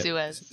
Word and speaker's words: Suez: Suez.